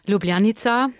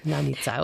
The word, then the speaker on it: Ljubljanica: Ja, heißt er Ljubljanica oder wie muss ich es aussprechen? – Ljubljanica. – Ljubljanica, okay.